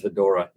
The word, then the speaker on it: Ghidorah: Ghidorah, yes. It's actually, well, like the American the American title is Godzilla versus the Smog Monster. – Okay. – Godzilla versus Ghidorah.